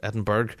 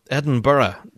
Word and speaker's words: Edinburgh: it's not Edinburgh, and it's not Edinburgh. I, see whoever puts that G in there as a hard G, Edinburgh. Totally wrong. Edinburgh. Yeah, there you go.